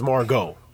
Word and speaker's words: Margot: Listen, is it Margot or Margot? – It's Margot. – Okay. – But the T is in remembrance.